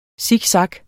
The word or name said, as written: siksak